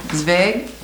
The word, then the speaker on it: Zwerg: Who can say it right? Zwerg? It's coming. Some of you Scandinavians should be able to. – Zwerg. – Okay, see, I know. Zwerg? – Zwerg. – Okay, now that's the way it would be in Swedish. – How is it? It's Z-W. E-R-G.